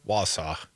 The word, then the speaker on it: Wausau: That's Bow Falls Radio up there in Warsaw. Wausau. I know how you say it.